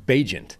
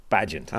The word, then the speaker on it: Bagent: Apparently, rookie quarterback for the Chicago Bears, his name is actually pronounced Bagent, not Bagent.